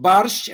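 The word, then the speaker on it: barszcz: I mean, there is a Polish borscht, but it's pronounced barsch, and it's a bit different.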